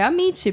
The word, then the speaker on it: Amici: É Amici, né? É. Com dois Cs, né? E a gente fala certo, é Amit, porque Amit, Amit é amigos. – Amigos em italiano.